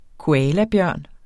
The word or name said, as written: koalabjørn